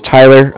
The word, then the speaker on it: Tyler: So my name is Tyler, T-Y-L-E-R, and I'm calling with Cardiff. It's spelled C-A-R-D-I-F-F, as in Frank.